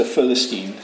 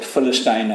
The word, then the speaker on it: Philistine: you are not able to go out against the Philistine, or Philistine as the Americans say, and fight him.